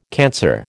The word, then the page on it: cancer: American English